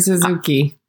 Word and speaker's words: Suzuki: On the other hand, it was very interesting to see Kurt, I'm probably going to butcher his name, Suzuki. – No, you got that right. Kurt Suzuki.